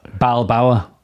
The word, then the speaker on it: Balboa: Rocky Bilbao. – Rocky Balboa. – Was it? What is he? Rocky, I've just written. Is he Bilboa or Balboa? – Was it not pronounced Rocky Balboa? – Balboa.